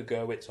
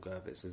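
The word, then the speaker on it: Gurvitz: But he's forced out of Nigeria, comes to Britain, and joins, or helps to form, the Baker Gerwitz Army in 1974. – You're pronouncing it with a W, yeah? – Is it a V? – Well, it's a V, isn't it?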